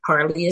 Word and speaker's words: Harlee: And so my email address is mhharlee, Harlee is spelled H-A-R-L-E-E, at wilmingtonde.gov.